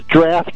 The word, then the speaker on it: draught: It's spelled D-R-A-U-G-H-T, huh? – Right, but it's pronounced D-R-A-F-T.